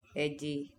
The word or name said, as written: eji